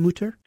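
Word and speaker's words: Mütter: but is that the way you pronounce his last name, Mutter? – You know, it's a disagreement, even among the Mutter Museum staff. Mutter was definitely born Tom Mutter without the umlaut.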